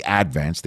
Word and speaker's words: Advance: And I took a job with my hometown newspaper, the Staten Island Advance, or as the locals would call it, the Advance. They put the accent on the first syllable.